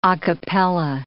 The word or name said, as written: a cappella